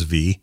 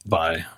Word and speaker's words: Vi: I think Hailee Steinfeld's great in everything she's in, but she is in particular doing great voice work here as Vi. Is it Vi or V? – Vi.